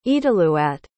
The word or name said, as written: etlelooaat